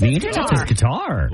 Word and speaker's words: Qatar: Oh, is it Qatar? – It's Qatar. – Qatar. – Oh, that's Qatar. – Ooh, there's controversy. – Controversy. While Qatar is a common mispronunciation, Qatar is what the government says to, so yeah, Qatar.